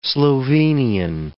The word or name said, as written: Slovenian